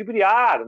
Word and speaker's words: briar: Por exemplo, uh, o pessoal fala muito na maneira de briar, né? Para mim é Briar, porque aí, nesse caso, é uma, é, uma, é uma palavra inglesa que tem a pronúncia em inglês.